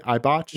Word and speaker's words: Eibach: And then here's the Konies, the stock Eibach, I think. Is that how you say it? Eibach? – Mm-hmm. Yeah. Eibach. Yeah.